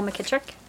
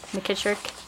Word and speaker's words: McKitrick: Nicole McKittrick. Nicole, N-I-C-O-L-E, McKittrick, M-C-K-I-T-R-I-C-K.